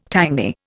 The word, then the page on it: tangy: Indian